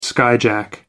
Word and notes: skyjack: American English

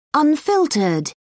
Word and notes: unfiltered: British English

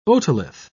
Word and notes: otolith: British English

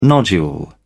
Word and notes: nodule: British English

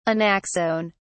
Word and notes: anaxone: British English

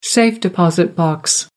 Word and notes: safe-deposit box: American English

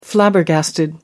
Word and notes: flabbergasted: British English

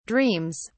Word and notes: dreams: British English

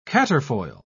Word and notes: quatrefoil: British English

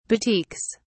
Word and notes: batiks: British English

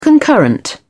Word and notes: concurrent: British English

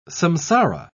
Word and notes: samsara: British English

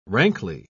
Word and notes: rankly: British English

